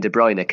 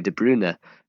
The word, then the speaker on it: De Bruyne: I call Kevin De Bruyne Kevin De Bruyne, but in actual fact, it's Kevin De Bruyne. His correct pronunciation is Kevin De Bruyne. And I've just gone with the trend, really, there.